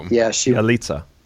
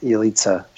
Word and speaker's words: Yalitza: I thought the woman, I can't really pronounce her name, but in Roma she was so Yalitza. – Yalitza.